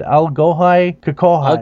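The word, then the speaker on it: Allghoi khorkhoi: Algohai Kokohai. – Algohai Kokohai. Kokori. – I'll spell it. A-L-L-G-H-O-I, which... Algohai, yeah. – K-H-O-R-K-H-O-I.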